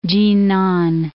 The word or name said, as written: Tsinan